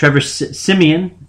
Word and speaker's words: Siemian: Trevor Siemian, as I found out his name is pronounced, did pretty good.